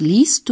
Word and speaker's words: liest: De e pasa a ser una i larga que se escribe ie. Escuchen de nuevo. – Lesen. – Was liest du? – Was liest du denn da?